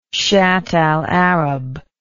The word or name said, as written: shatt al arab